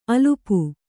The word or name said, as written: ಅಲ್ಪ